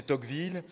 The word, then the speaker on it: Tocqueville: J'ai essayé de le prononcer à la française, pas à la méridionale. Hein. Ça nous donnerait, naturellement, si je ne me contraignais pas pour vous, ça donnerait Tocqueville. Voilà, mais là, je vais prononcer Tocqueville.